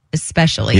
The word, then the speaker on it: especially: There's no X in the word especially.